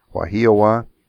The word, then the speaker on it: Wahiawa: Let me make sure I get it pronounced right. Wahiawaha. No. Wahiawa. That's it.